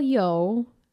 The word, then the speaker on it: Yeoh: Actress Michelle Yeoh.